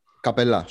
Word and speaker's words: Καπελά: Δηλαδή τα τελευταία χρόνια ο Χάρντεν έχει τσακωθεί ακόμα και με τον Καπέλα. – Σωστό. Ε... Και είναι. – Ναι, ένα... και ο Embiid δεν είναι ε, καπέλα. Εγώ θα κρατήσω την ελβετική προφορά. Καπελά, σωστό.